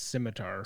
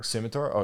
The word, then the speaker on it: scimitar: It's pronounced scimitar. – Scimitar? Oh, yeah, sorry. Yeah, scimitar. Yeah.